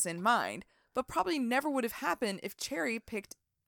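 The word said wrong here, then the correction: Cherie: Is it Sherry? It's Sherry. I keep calling her Cherry. It's Sherry. – It's Sherry, but it's spelled like Cherry.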